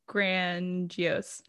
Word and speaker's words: grandiose: It's grandiose. it's grandiose, she says with confidence. – Is that how you pronounce that word? – Grandiose. you got it.